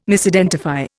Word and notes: Misidentify: Indian

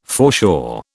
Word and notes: foreshore: British English

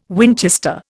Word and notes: Winchester: Indian

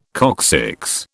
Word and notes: coccyx: British English

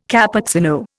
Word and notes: Cappuccino: Indian

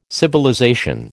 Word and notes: civilization: American English

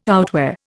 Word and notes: outwear: Indian

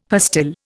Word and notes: pastille: Indian

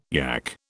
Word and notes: yack: British English

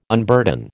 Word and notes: unburden: American English

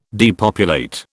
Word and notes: depopulate: British English

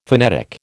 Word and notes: phonetic: American English

